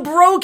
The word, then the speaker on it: Breaux: Oh, Bro Capital, do you get it? It's spelled the French way. B-R-E-A-U-X, Bro Capital?